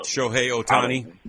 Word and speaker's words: Shohei Ohtani: But Shohei Ohtani.